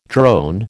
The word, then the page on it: drone: American English